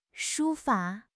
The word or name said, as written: 书法